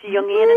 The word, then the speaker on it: Jungian: Yes, Jungian analysis.